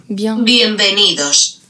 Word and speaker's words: Bienvenidos: Was sagt denn Google hier? – Bienvenidos. Ah, okay. Bien, ah. Bienvenidos. Ja.